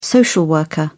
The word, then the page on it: social worker: British English